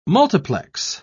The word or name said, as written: multiplex